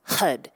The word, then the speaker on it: Har: The chant is spelled H-A-R, but it's pronounced HUD.